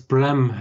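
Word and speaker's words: Blame!: Wie ich dann durch den Trailer für eine Netflix-Originalumsetzung von dem Thema äh, drauf gekommen bin, habe ich dann rausgekriegt, dass es Bram heißt. Also dieses L spricht man dann doch tendenziell eher wie ein R, aber eher so wie ein bisschen Zwischending, das typische japanische Thema halt.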